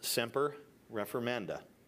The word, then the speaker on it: semper reformanda: It is pronounced semper reformanda.